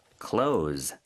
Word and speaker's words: close: 그래서 빈칸에는 close, C-L-O-S-E. – 네, S-E지만 이렇게 동사일 때 close, Z 발음으로 해주셔야 합니다.